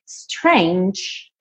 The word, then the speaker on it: strange: Strange, você não pronuncia a letra E, mas essa letra G, ela tem esse som de DJ, j, j, j, j, ok?